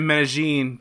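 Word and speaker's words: Medellín: When did Medellin do as the Medellins do? – You're pronouncing it right.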